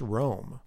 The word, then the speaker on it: room: But as we remember, there's a little bit of a pun there, which is that room used to be pronounced Rome. It's almost like they're yelling Rome for Antony.